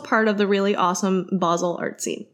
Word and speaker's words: Basel: She picked him up in Basel. That's actually how you pronounce it.